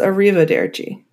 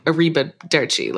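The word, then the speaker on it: Arrivederci: Well, it is pronounced that way. It's pronounced Arriva Derci. – Yeah, but that's what I mean. Like, I wouldn't, my mind wouldn't immediately be like Arriba Derci.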